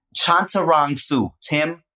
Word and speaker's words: Chantarangsu: No, just sound it out. It's just like it's spelled. – It's Tim. – Ah, you're so close. You're so close. You know what? Chantarangsu. Tim Chantarangsu.